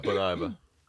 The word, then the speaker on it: Bon Iver: Wird das so ausgesprochen? Bon Iver? – Ja, Bon Iver.